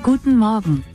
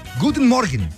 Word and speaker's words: Guten Morgen: Guten Morgen. – Guten Morgen. Ok, Guten Morgen. Buenos días, en alemán, Guten Morgen. Más lento, por favor. – Guten Morgen. – Guten Morgen. Listo ya. Guten Morgen.